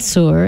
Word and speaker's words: Tsur: From his album Moments of Inspiration, this is Dean Soar. It's spelled T-S-U-R.